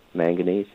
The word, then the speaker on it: manganese: It's going to be 15-0-15, and then I need some zinc, and I need some, I guess I'm pronouncing it, manganese? – Uh-huh, manganese, yeah. Well, low quantities on those two, so...